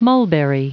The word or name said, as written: mulberry